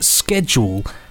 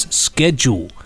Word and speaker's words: schedule: I have just listened to the first half of episode 1 of the Time War 2 box set, and the word schedule has twice been pronounced in the American way as schedule. – Which you've just done. – Schedule. Yeah. Schedule. As you know, in British English, the word is pronounced schedule, as Nick has already demonstrated his linguistic pedantry in recent podcasts over the pronunciation of the word harassment or harassment.